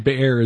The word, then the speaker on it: Beer: And Jotham ran away and fled and went to Beer and lived there because of Abimelech, his brother. Now, Abimelech ruled over Israel 3 years. – The Beer is the pronunciation, but it's spelled B-E-E-R.